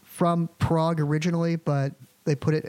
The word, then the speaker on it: Prague: So there's a town in Oklahoma that's spelled Prague, like the country, but it's pronounced Prague in the same way that Miami, Oklahoma is pronounced Miami, like officially.